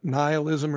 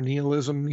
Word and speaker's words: nihilism: You've got nihilism or nihilism, you know, people pronounce it different ways, but essentially saying that life isn't real, nothing matters.